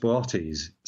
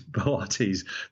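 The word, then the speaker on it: Boötes: It's usually pronounced Bortes, so there's two O's in it. It looks like booties, but it's Bortes. The second O has an umlaut over it to tell you that it is pronounced as a separate vowel.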